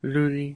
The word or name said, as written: luli